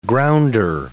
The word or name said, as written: grounder